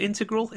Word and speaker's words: integral: So that is a pretty good performance from him, and he was integral. I can't say that word today. Is it integral? Integral? – Integral. – Integral. Well, a third pronunciation I considered.